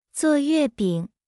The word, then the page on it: 做月饼: 0.8x